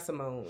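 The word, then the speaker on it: Symoné: It's not Simone. It's Simone.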